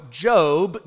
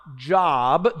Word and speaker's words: Job: Job is indeed pronounced, for those of you new to the Bible, Job, not Job, though it's spelled the same.